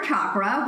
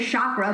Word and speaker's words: chakra: called Core Chakra, which I always say chakra, but it's chakra.